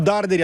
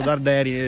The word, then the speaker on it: Darderi: Ma su Darderi abbiamo ancora qualche problema, continuo a pronunciarlo Darderi e devo dirgli che si pronuncia Darderi.